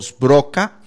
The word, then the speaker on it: Sbroca: Primero, nada más, para las personas que se vienen conectando a este programa, estamos aquí con Rodrigo Goñi y Leonardo Sbroca. Espero haber pronunciado bien su apellido, pues es un apellido muy poco común, perfecto, por lo menos en Costa Rica. – En un puro italiano costarricense te salió perfecto.